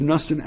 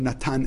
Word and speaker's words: Natan: One was Rav Nussan Adler, actually who pronounced his name Rav Natan Adler, and the other one was Rav Pinchas Halevi Horowitz, who's known as the Hafla.